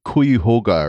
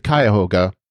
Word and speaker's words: Cuyahoga: Lieutenant Willa mispronounced the name of the USS Cuyahoga. She said something like Cuyahoga or something. And of course, that is the Cuyahoga.